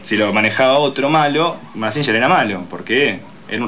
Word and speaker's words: Mazinger: Mazinger, que en realidad en japonés creo se pronuncia Mazinga, y significa dios o demonio, depende quién lo pilotee.